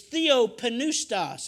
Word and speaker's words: theopneustos: It's theopneustos. That's how it's pronounced in the Greek, if I'm not mistaken. You pronounce the P. We do not. We call it pneumonia.